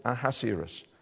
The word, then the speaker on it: Ahasuerus: You might, if you're using the ESV, you might know him as Ahasuerus. That's it, Ahasuerus.